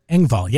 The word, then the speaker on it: Engvall: Man, Brandy says, Bill Engvall. Did I say that right? – Engvall, yeah.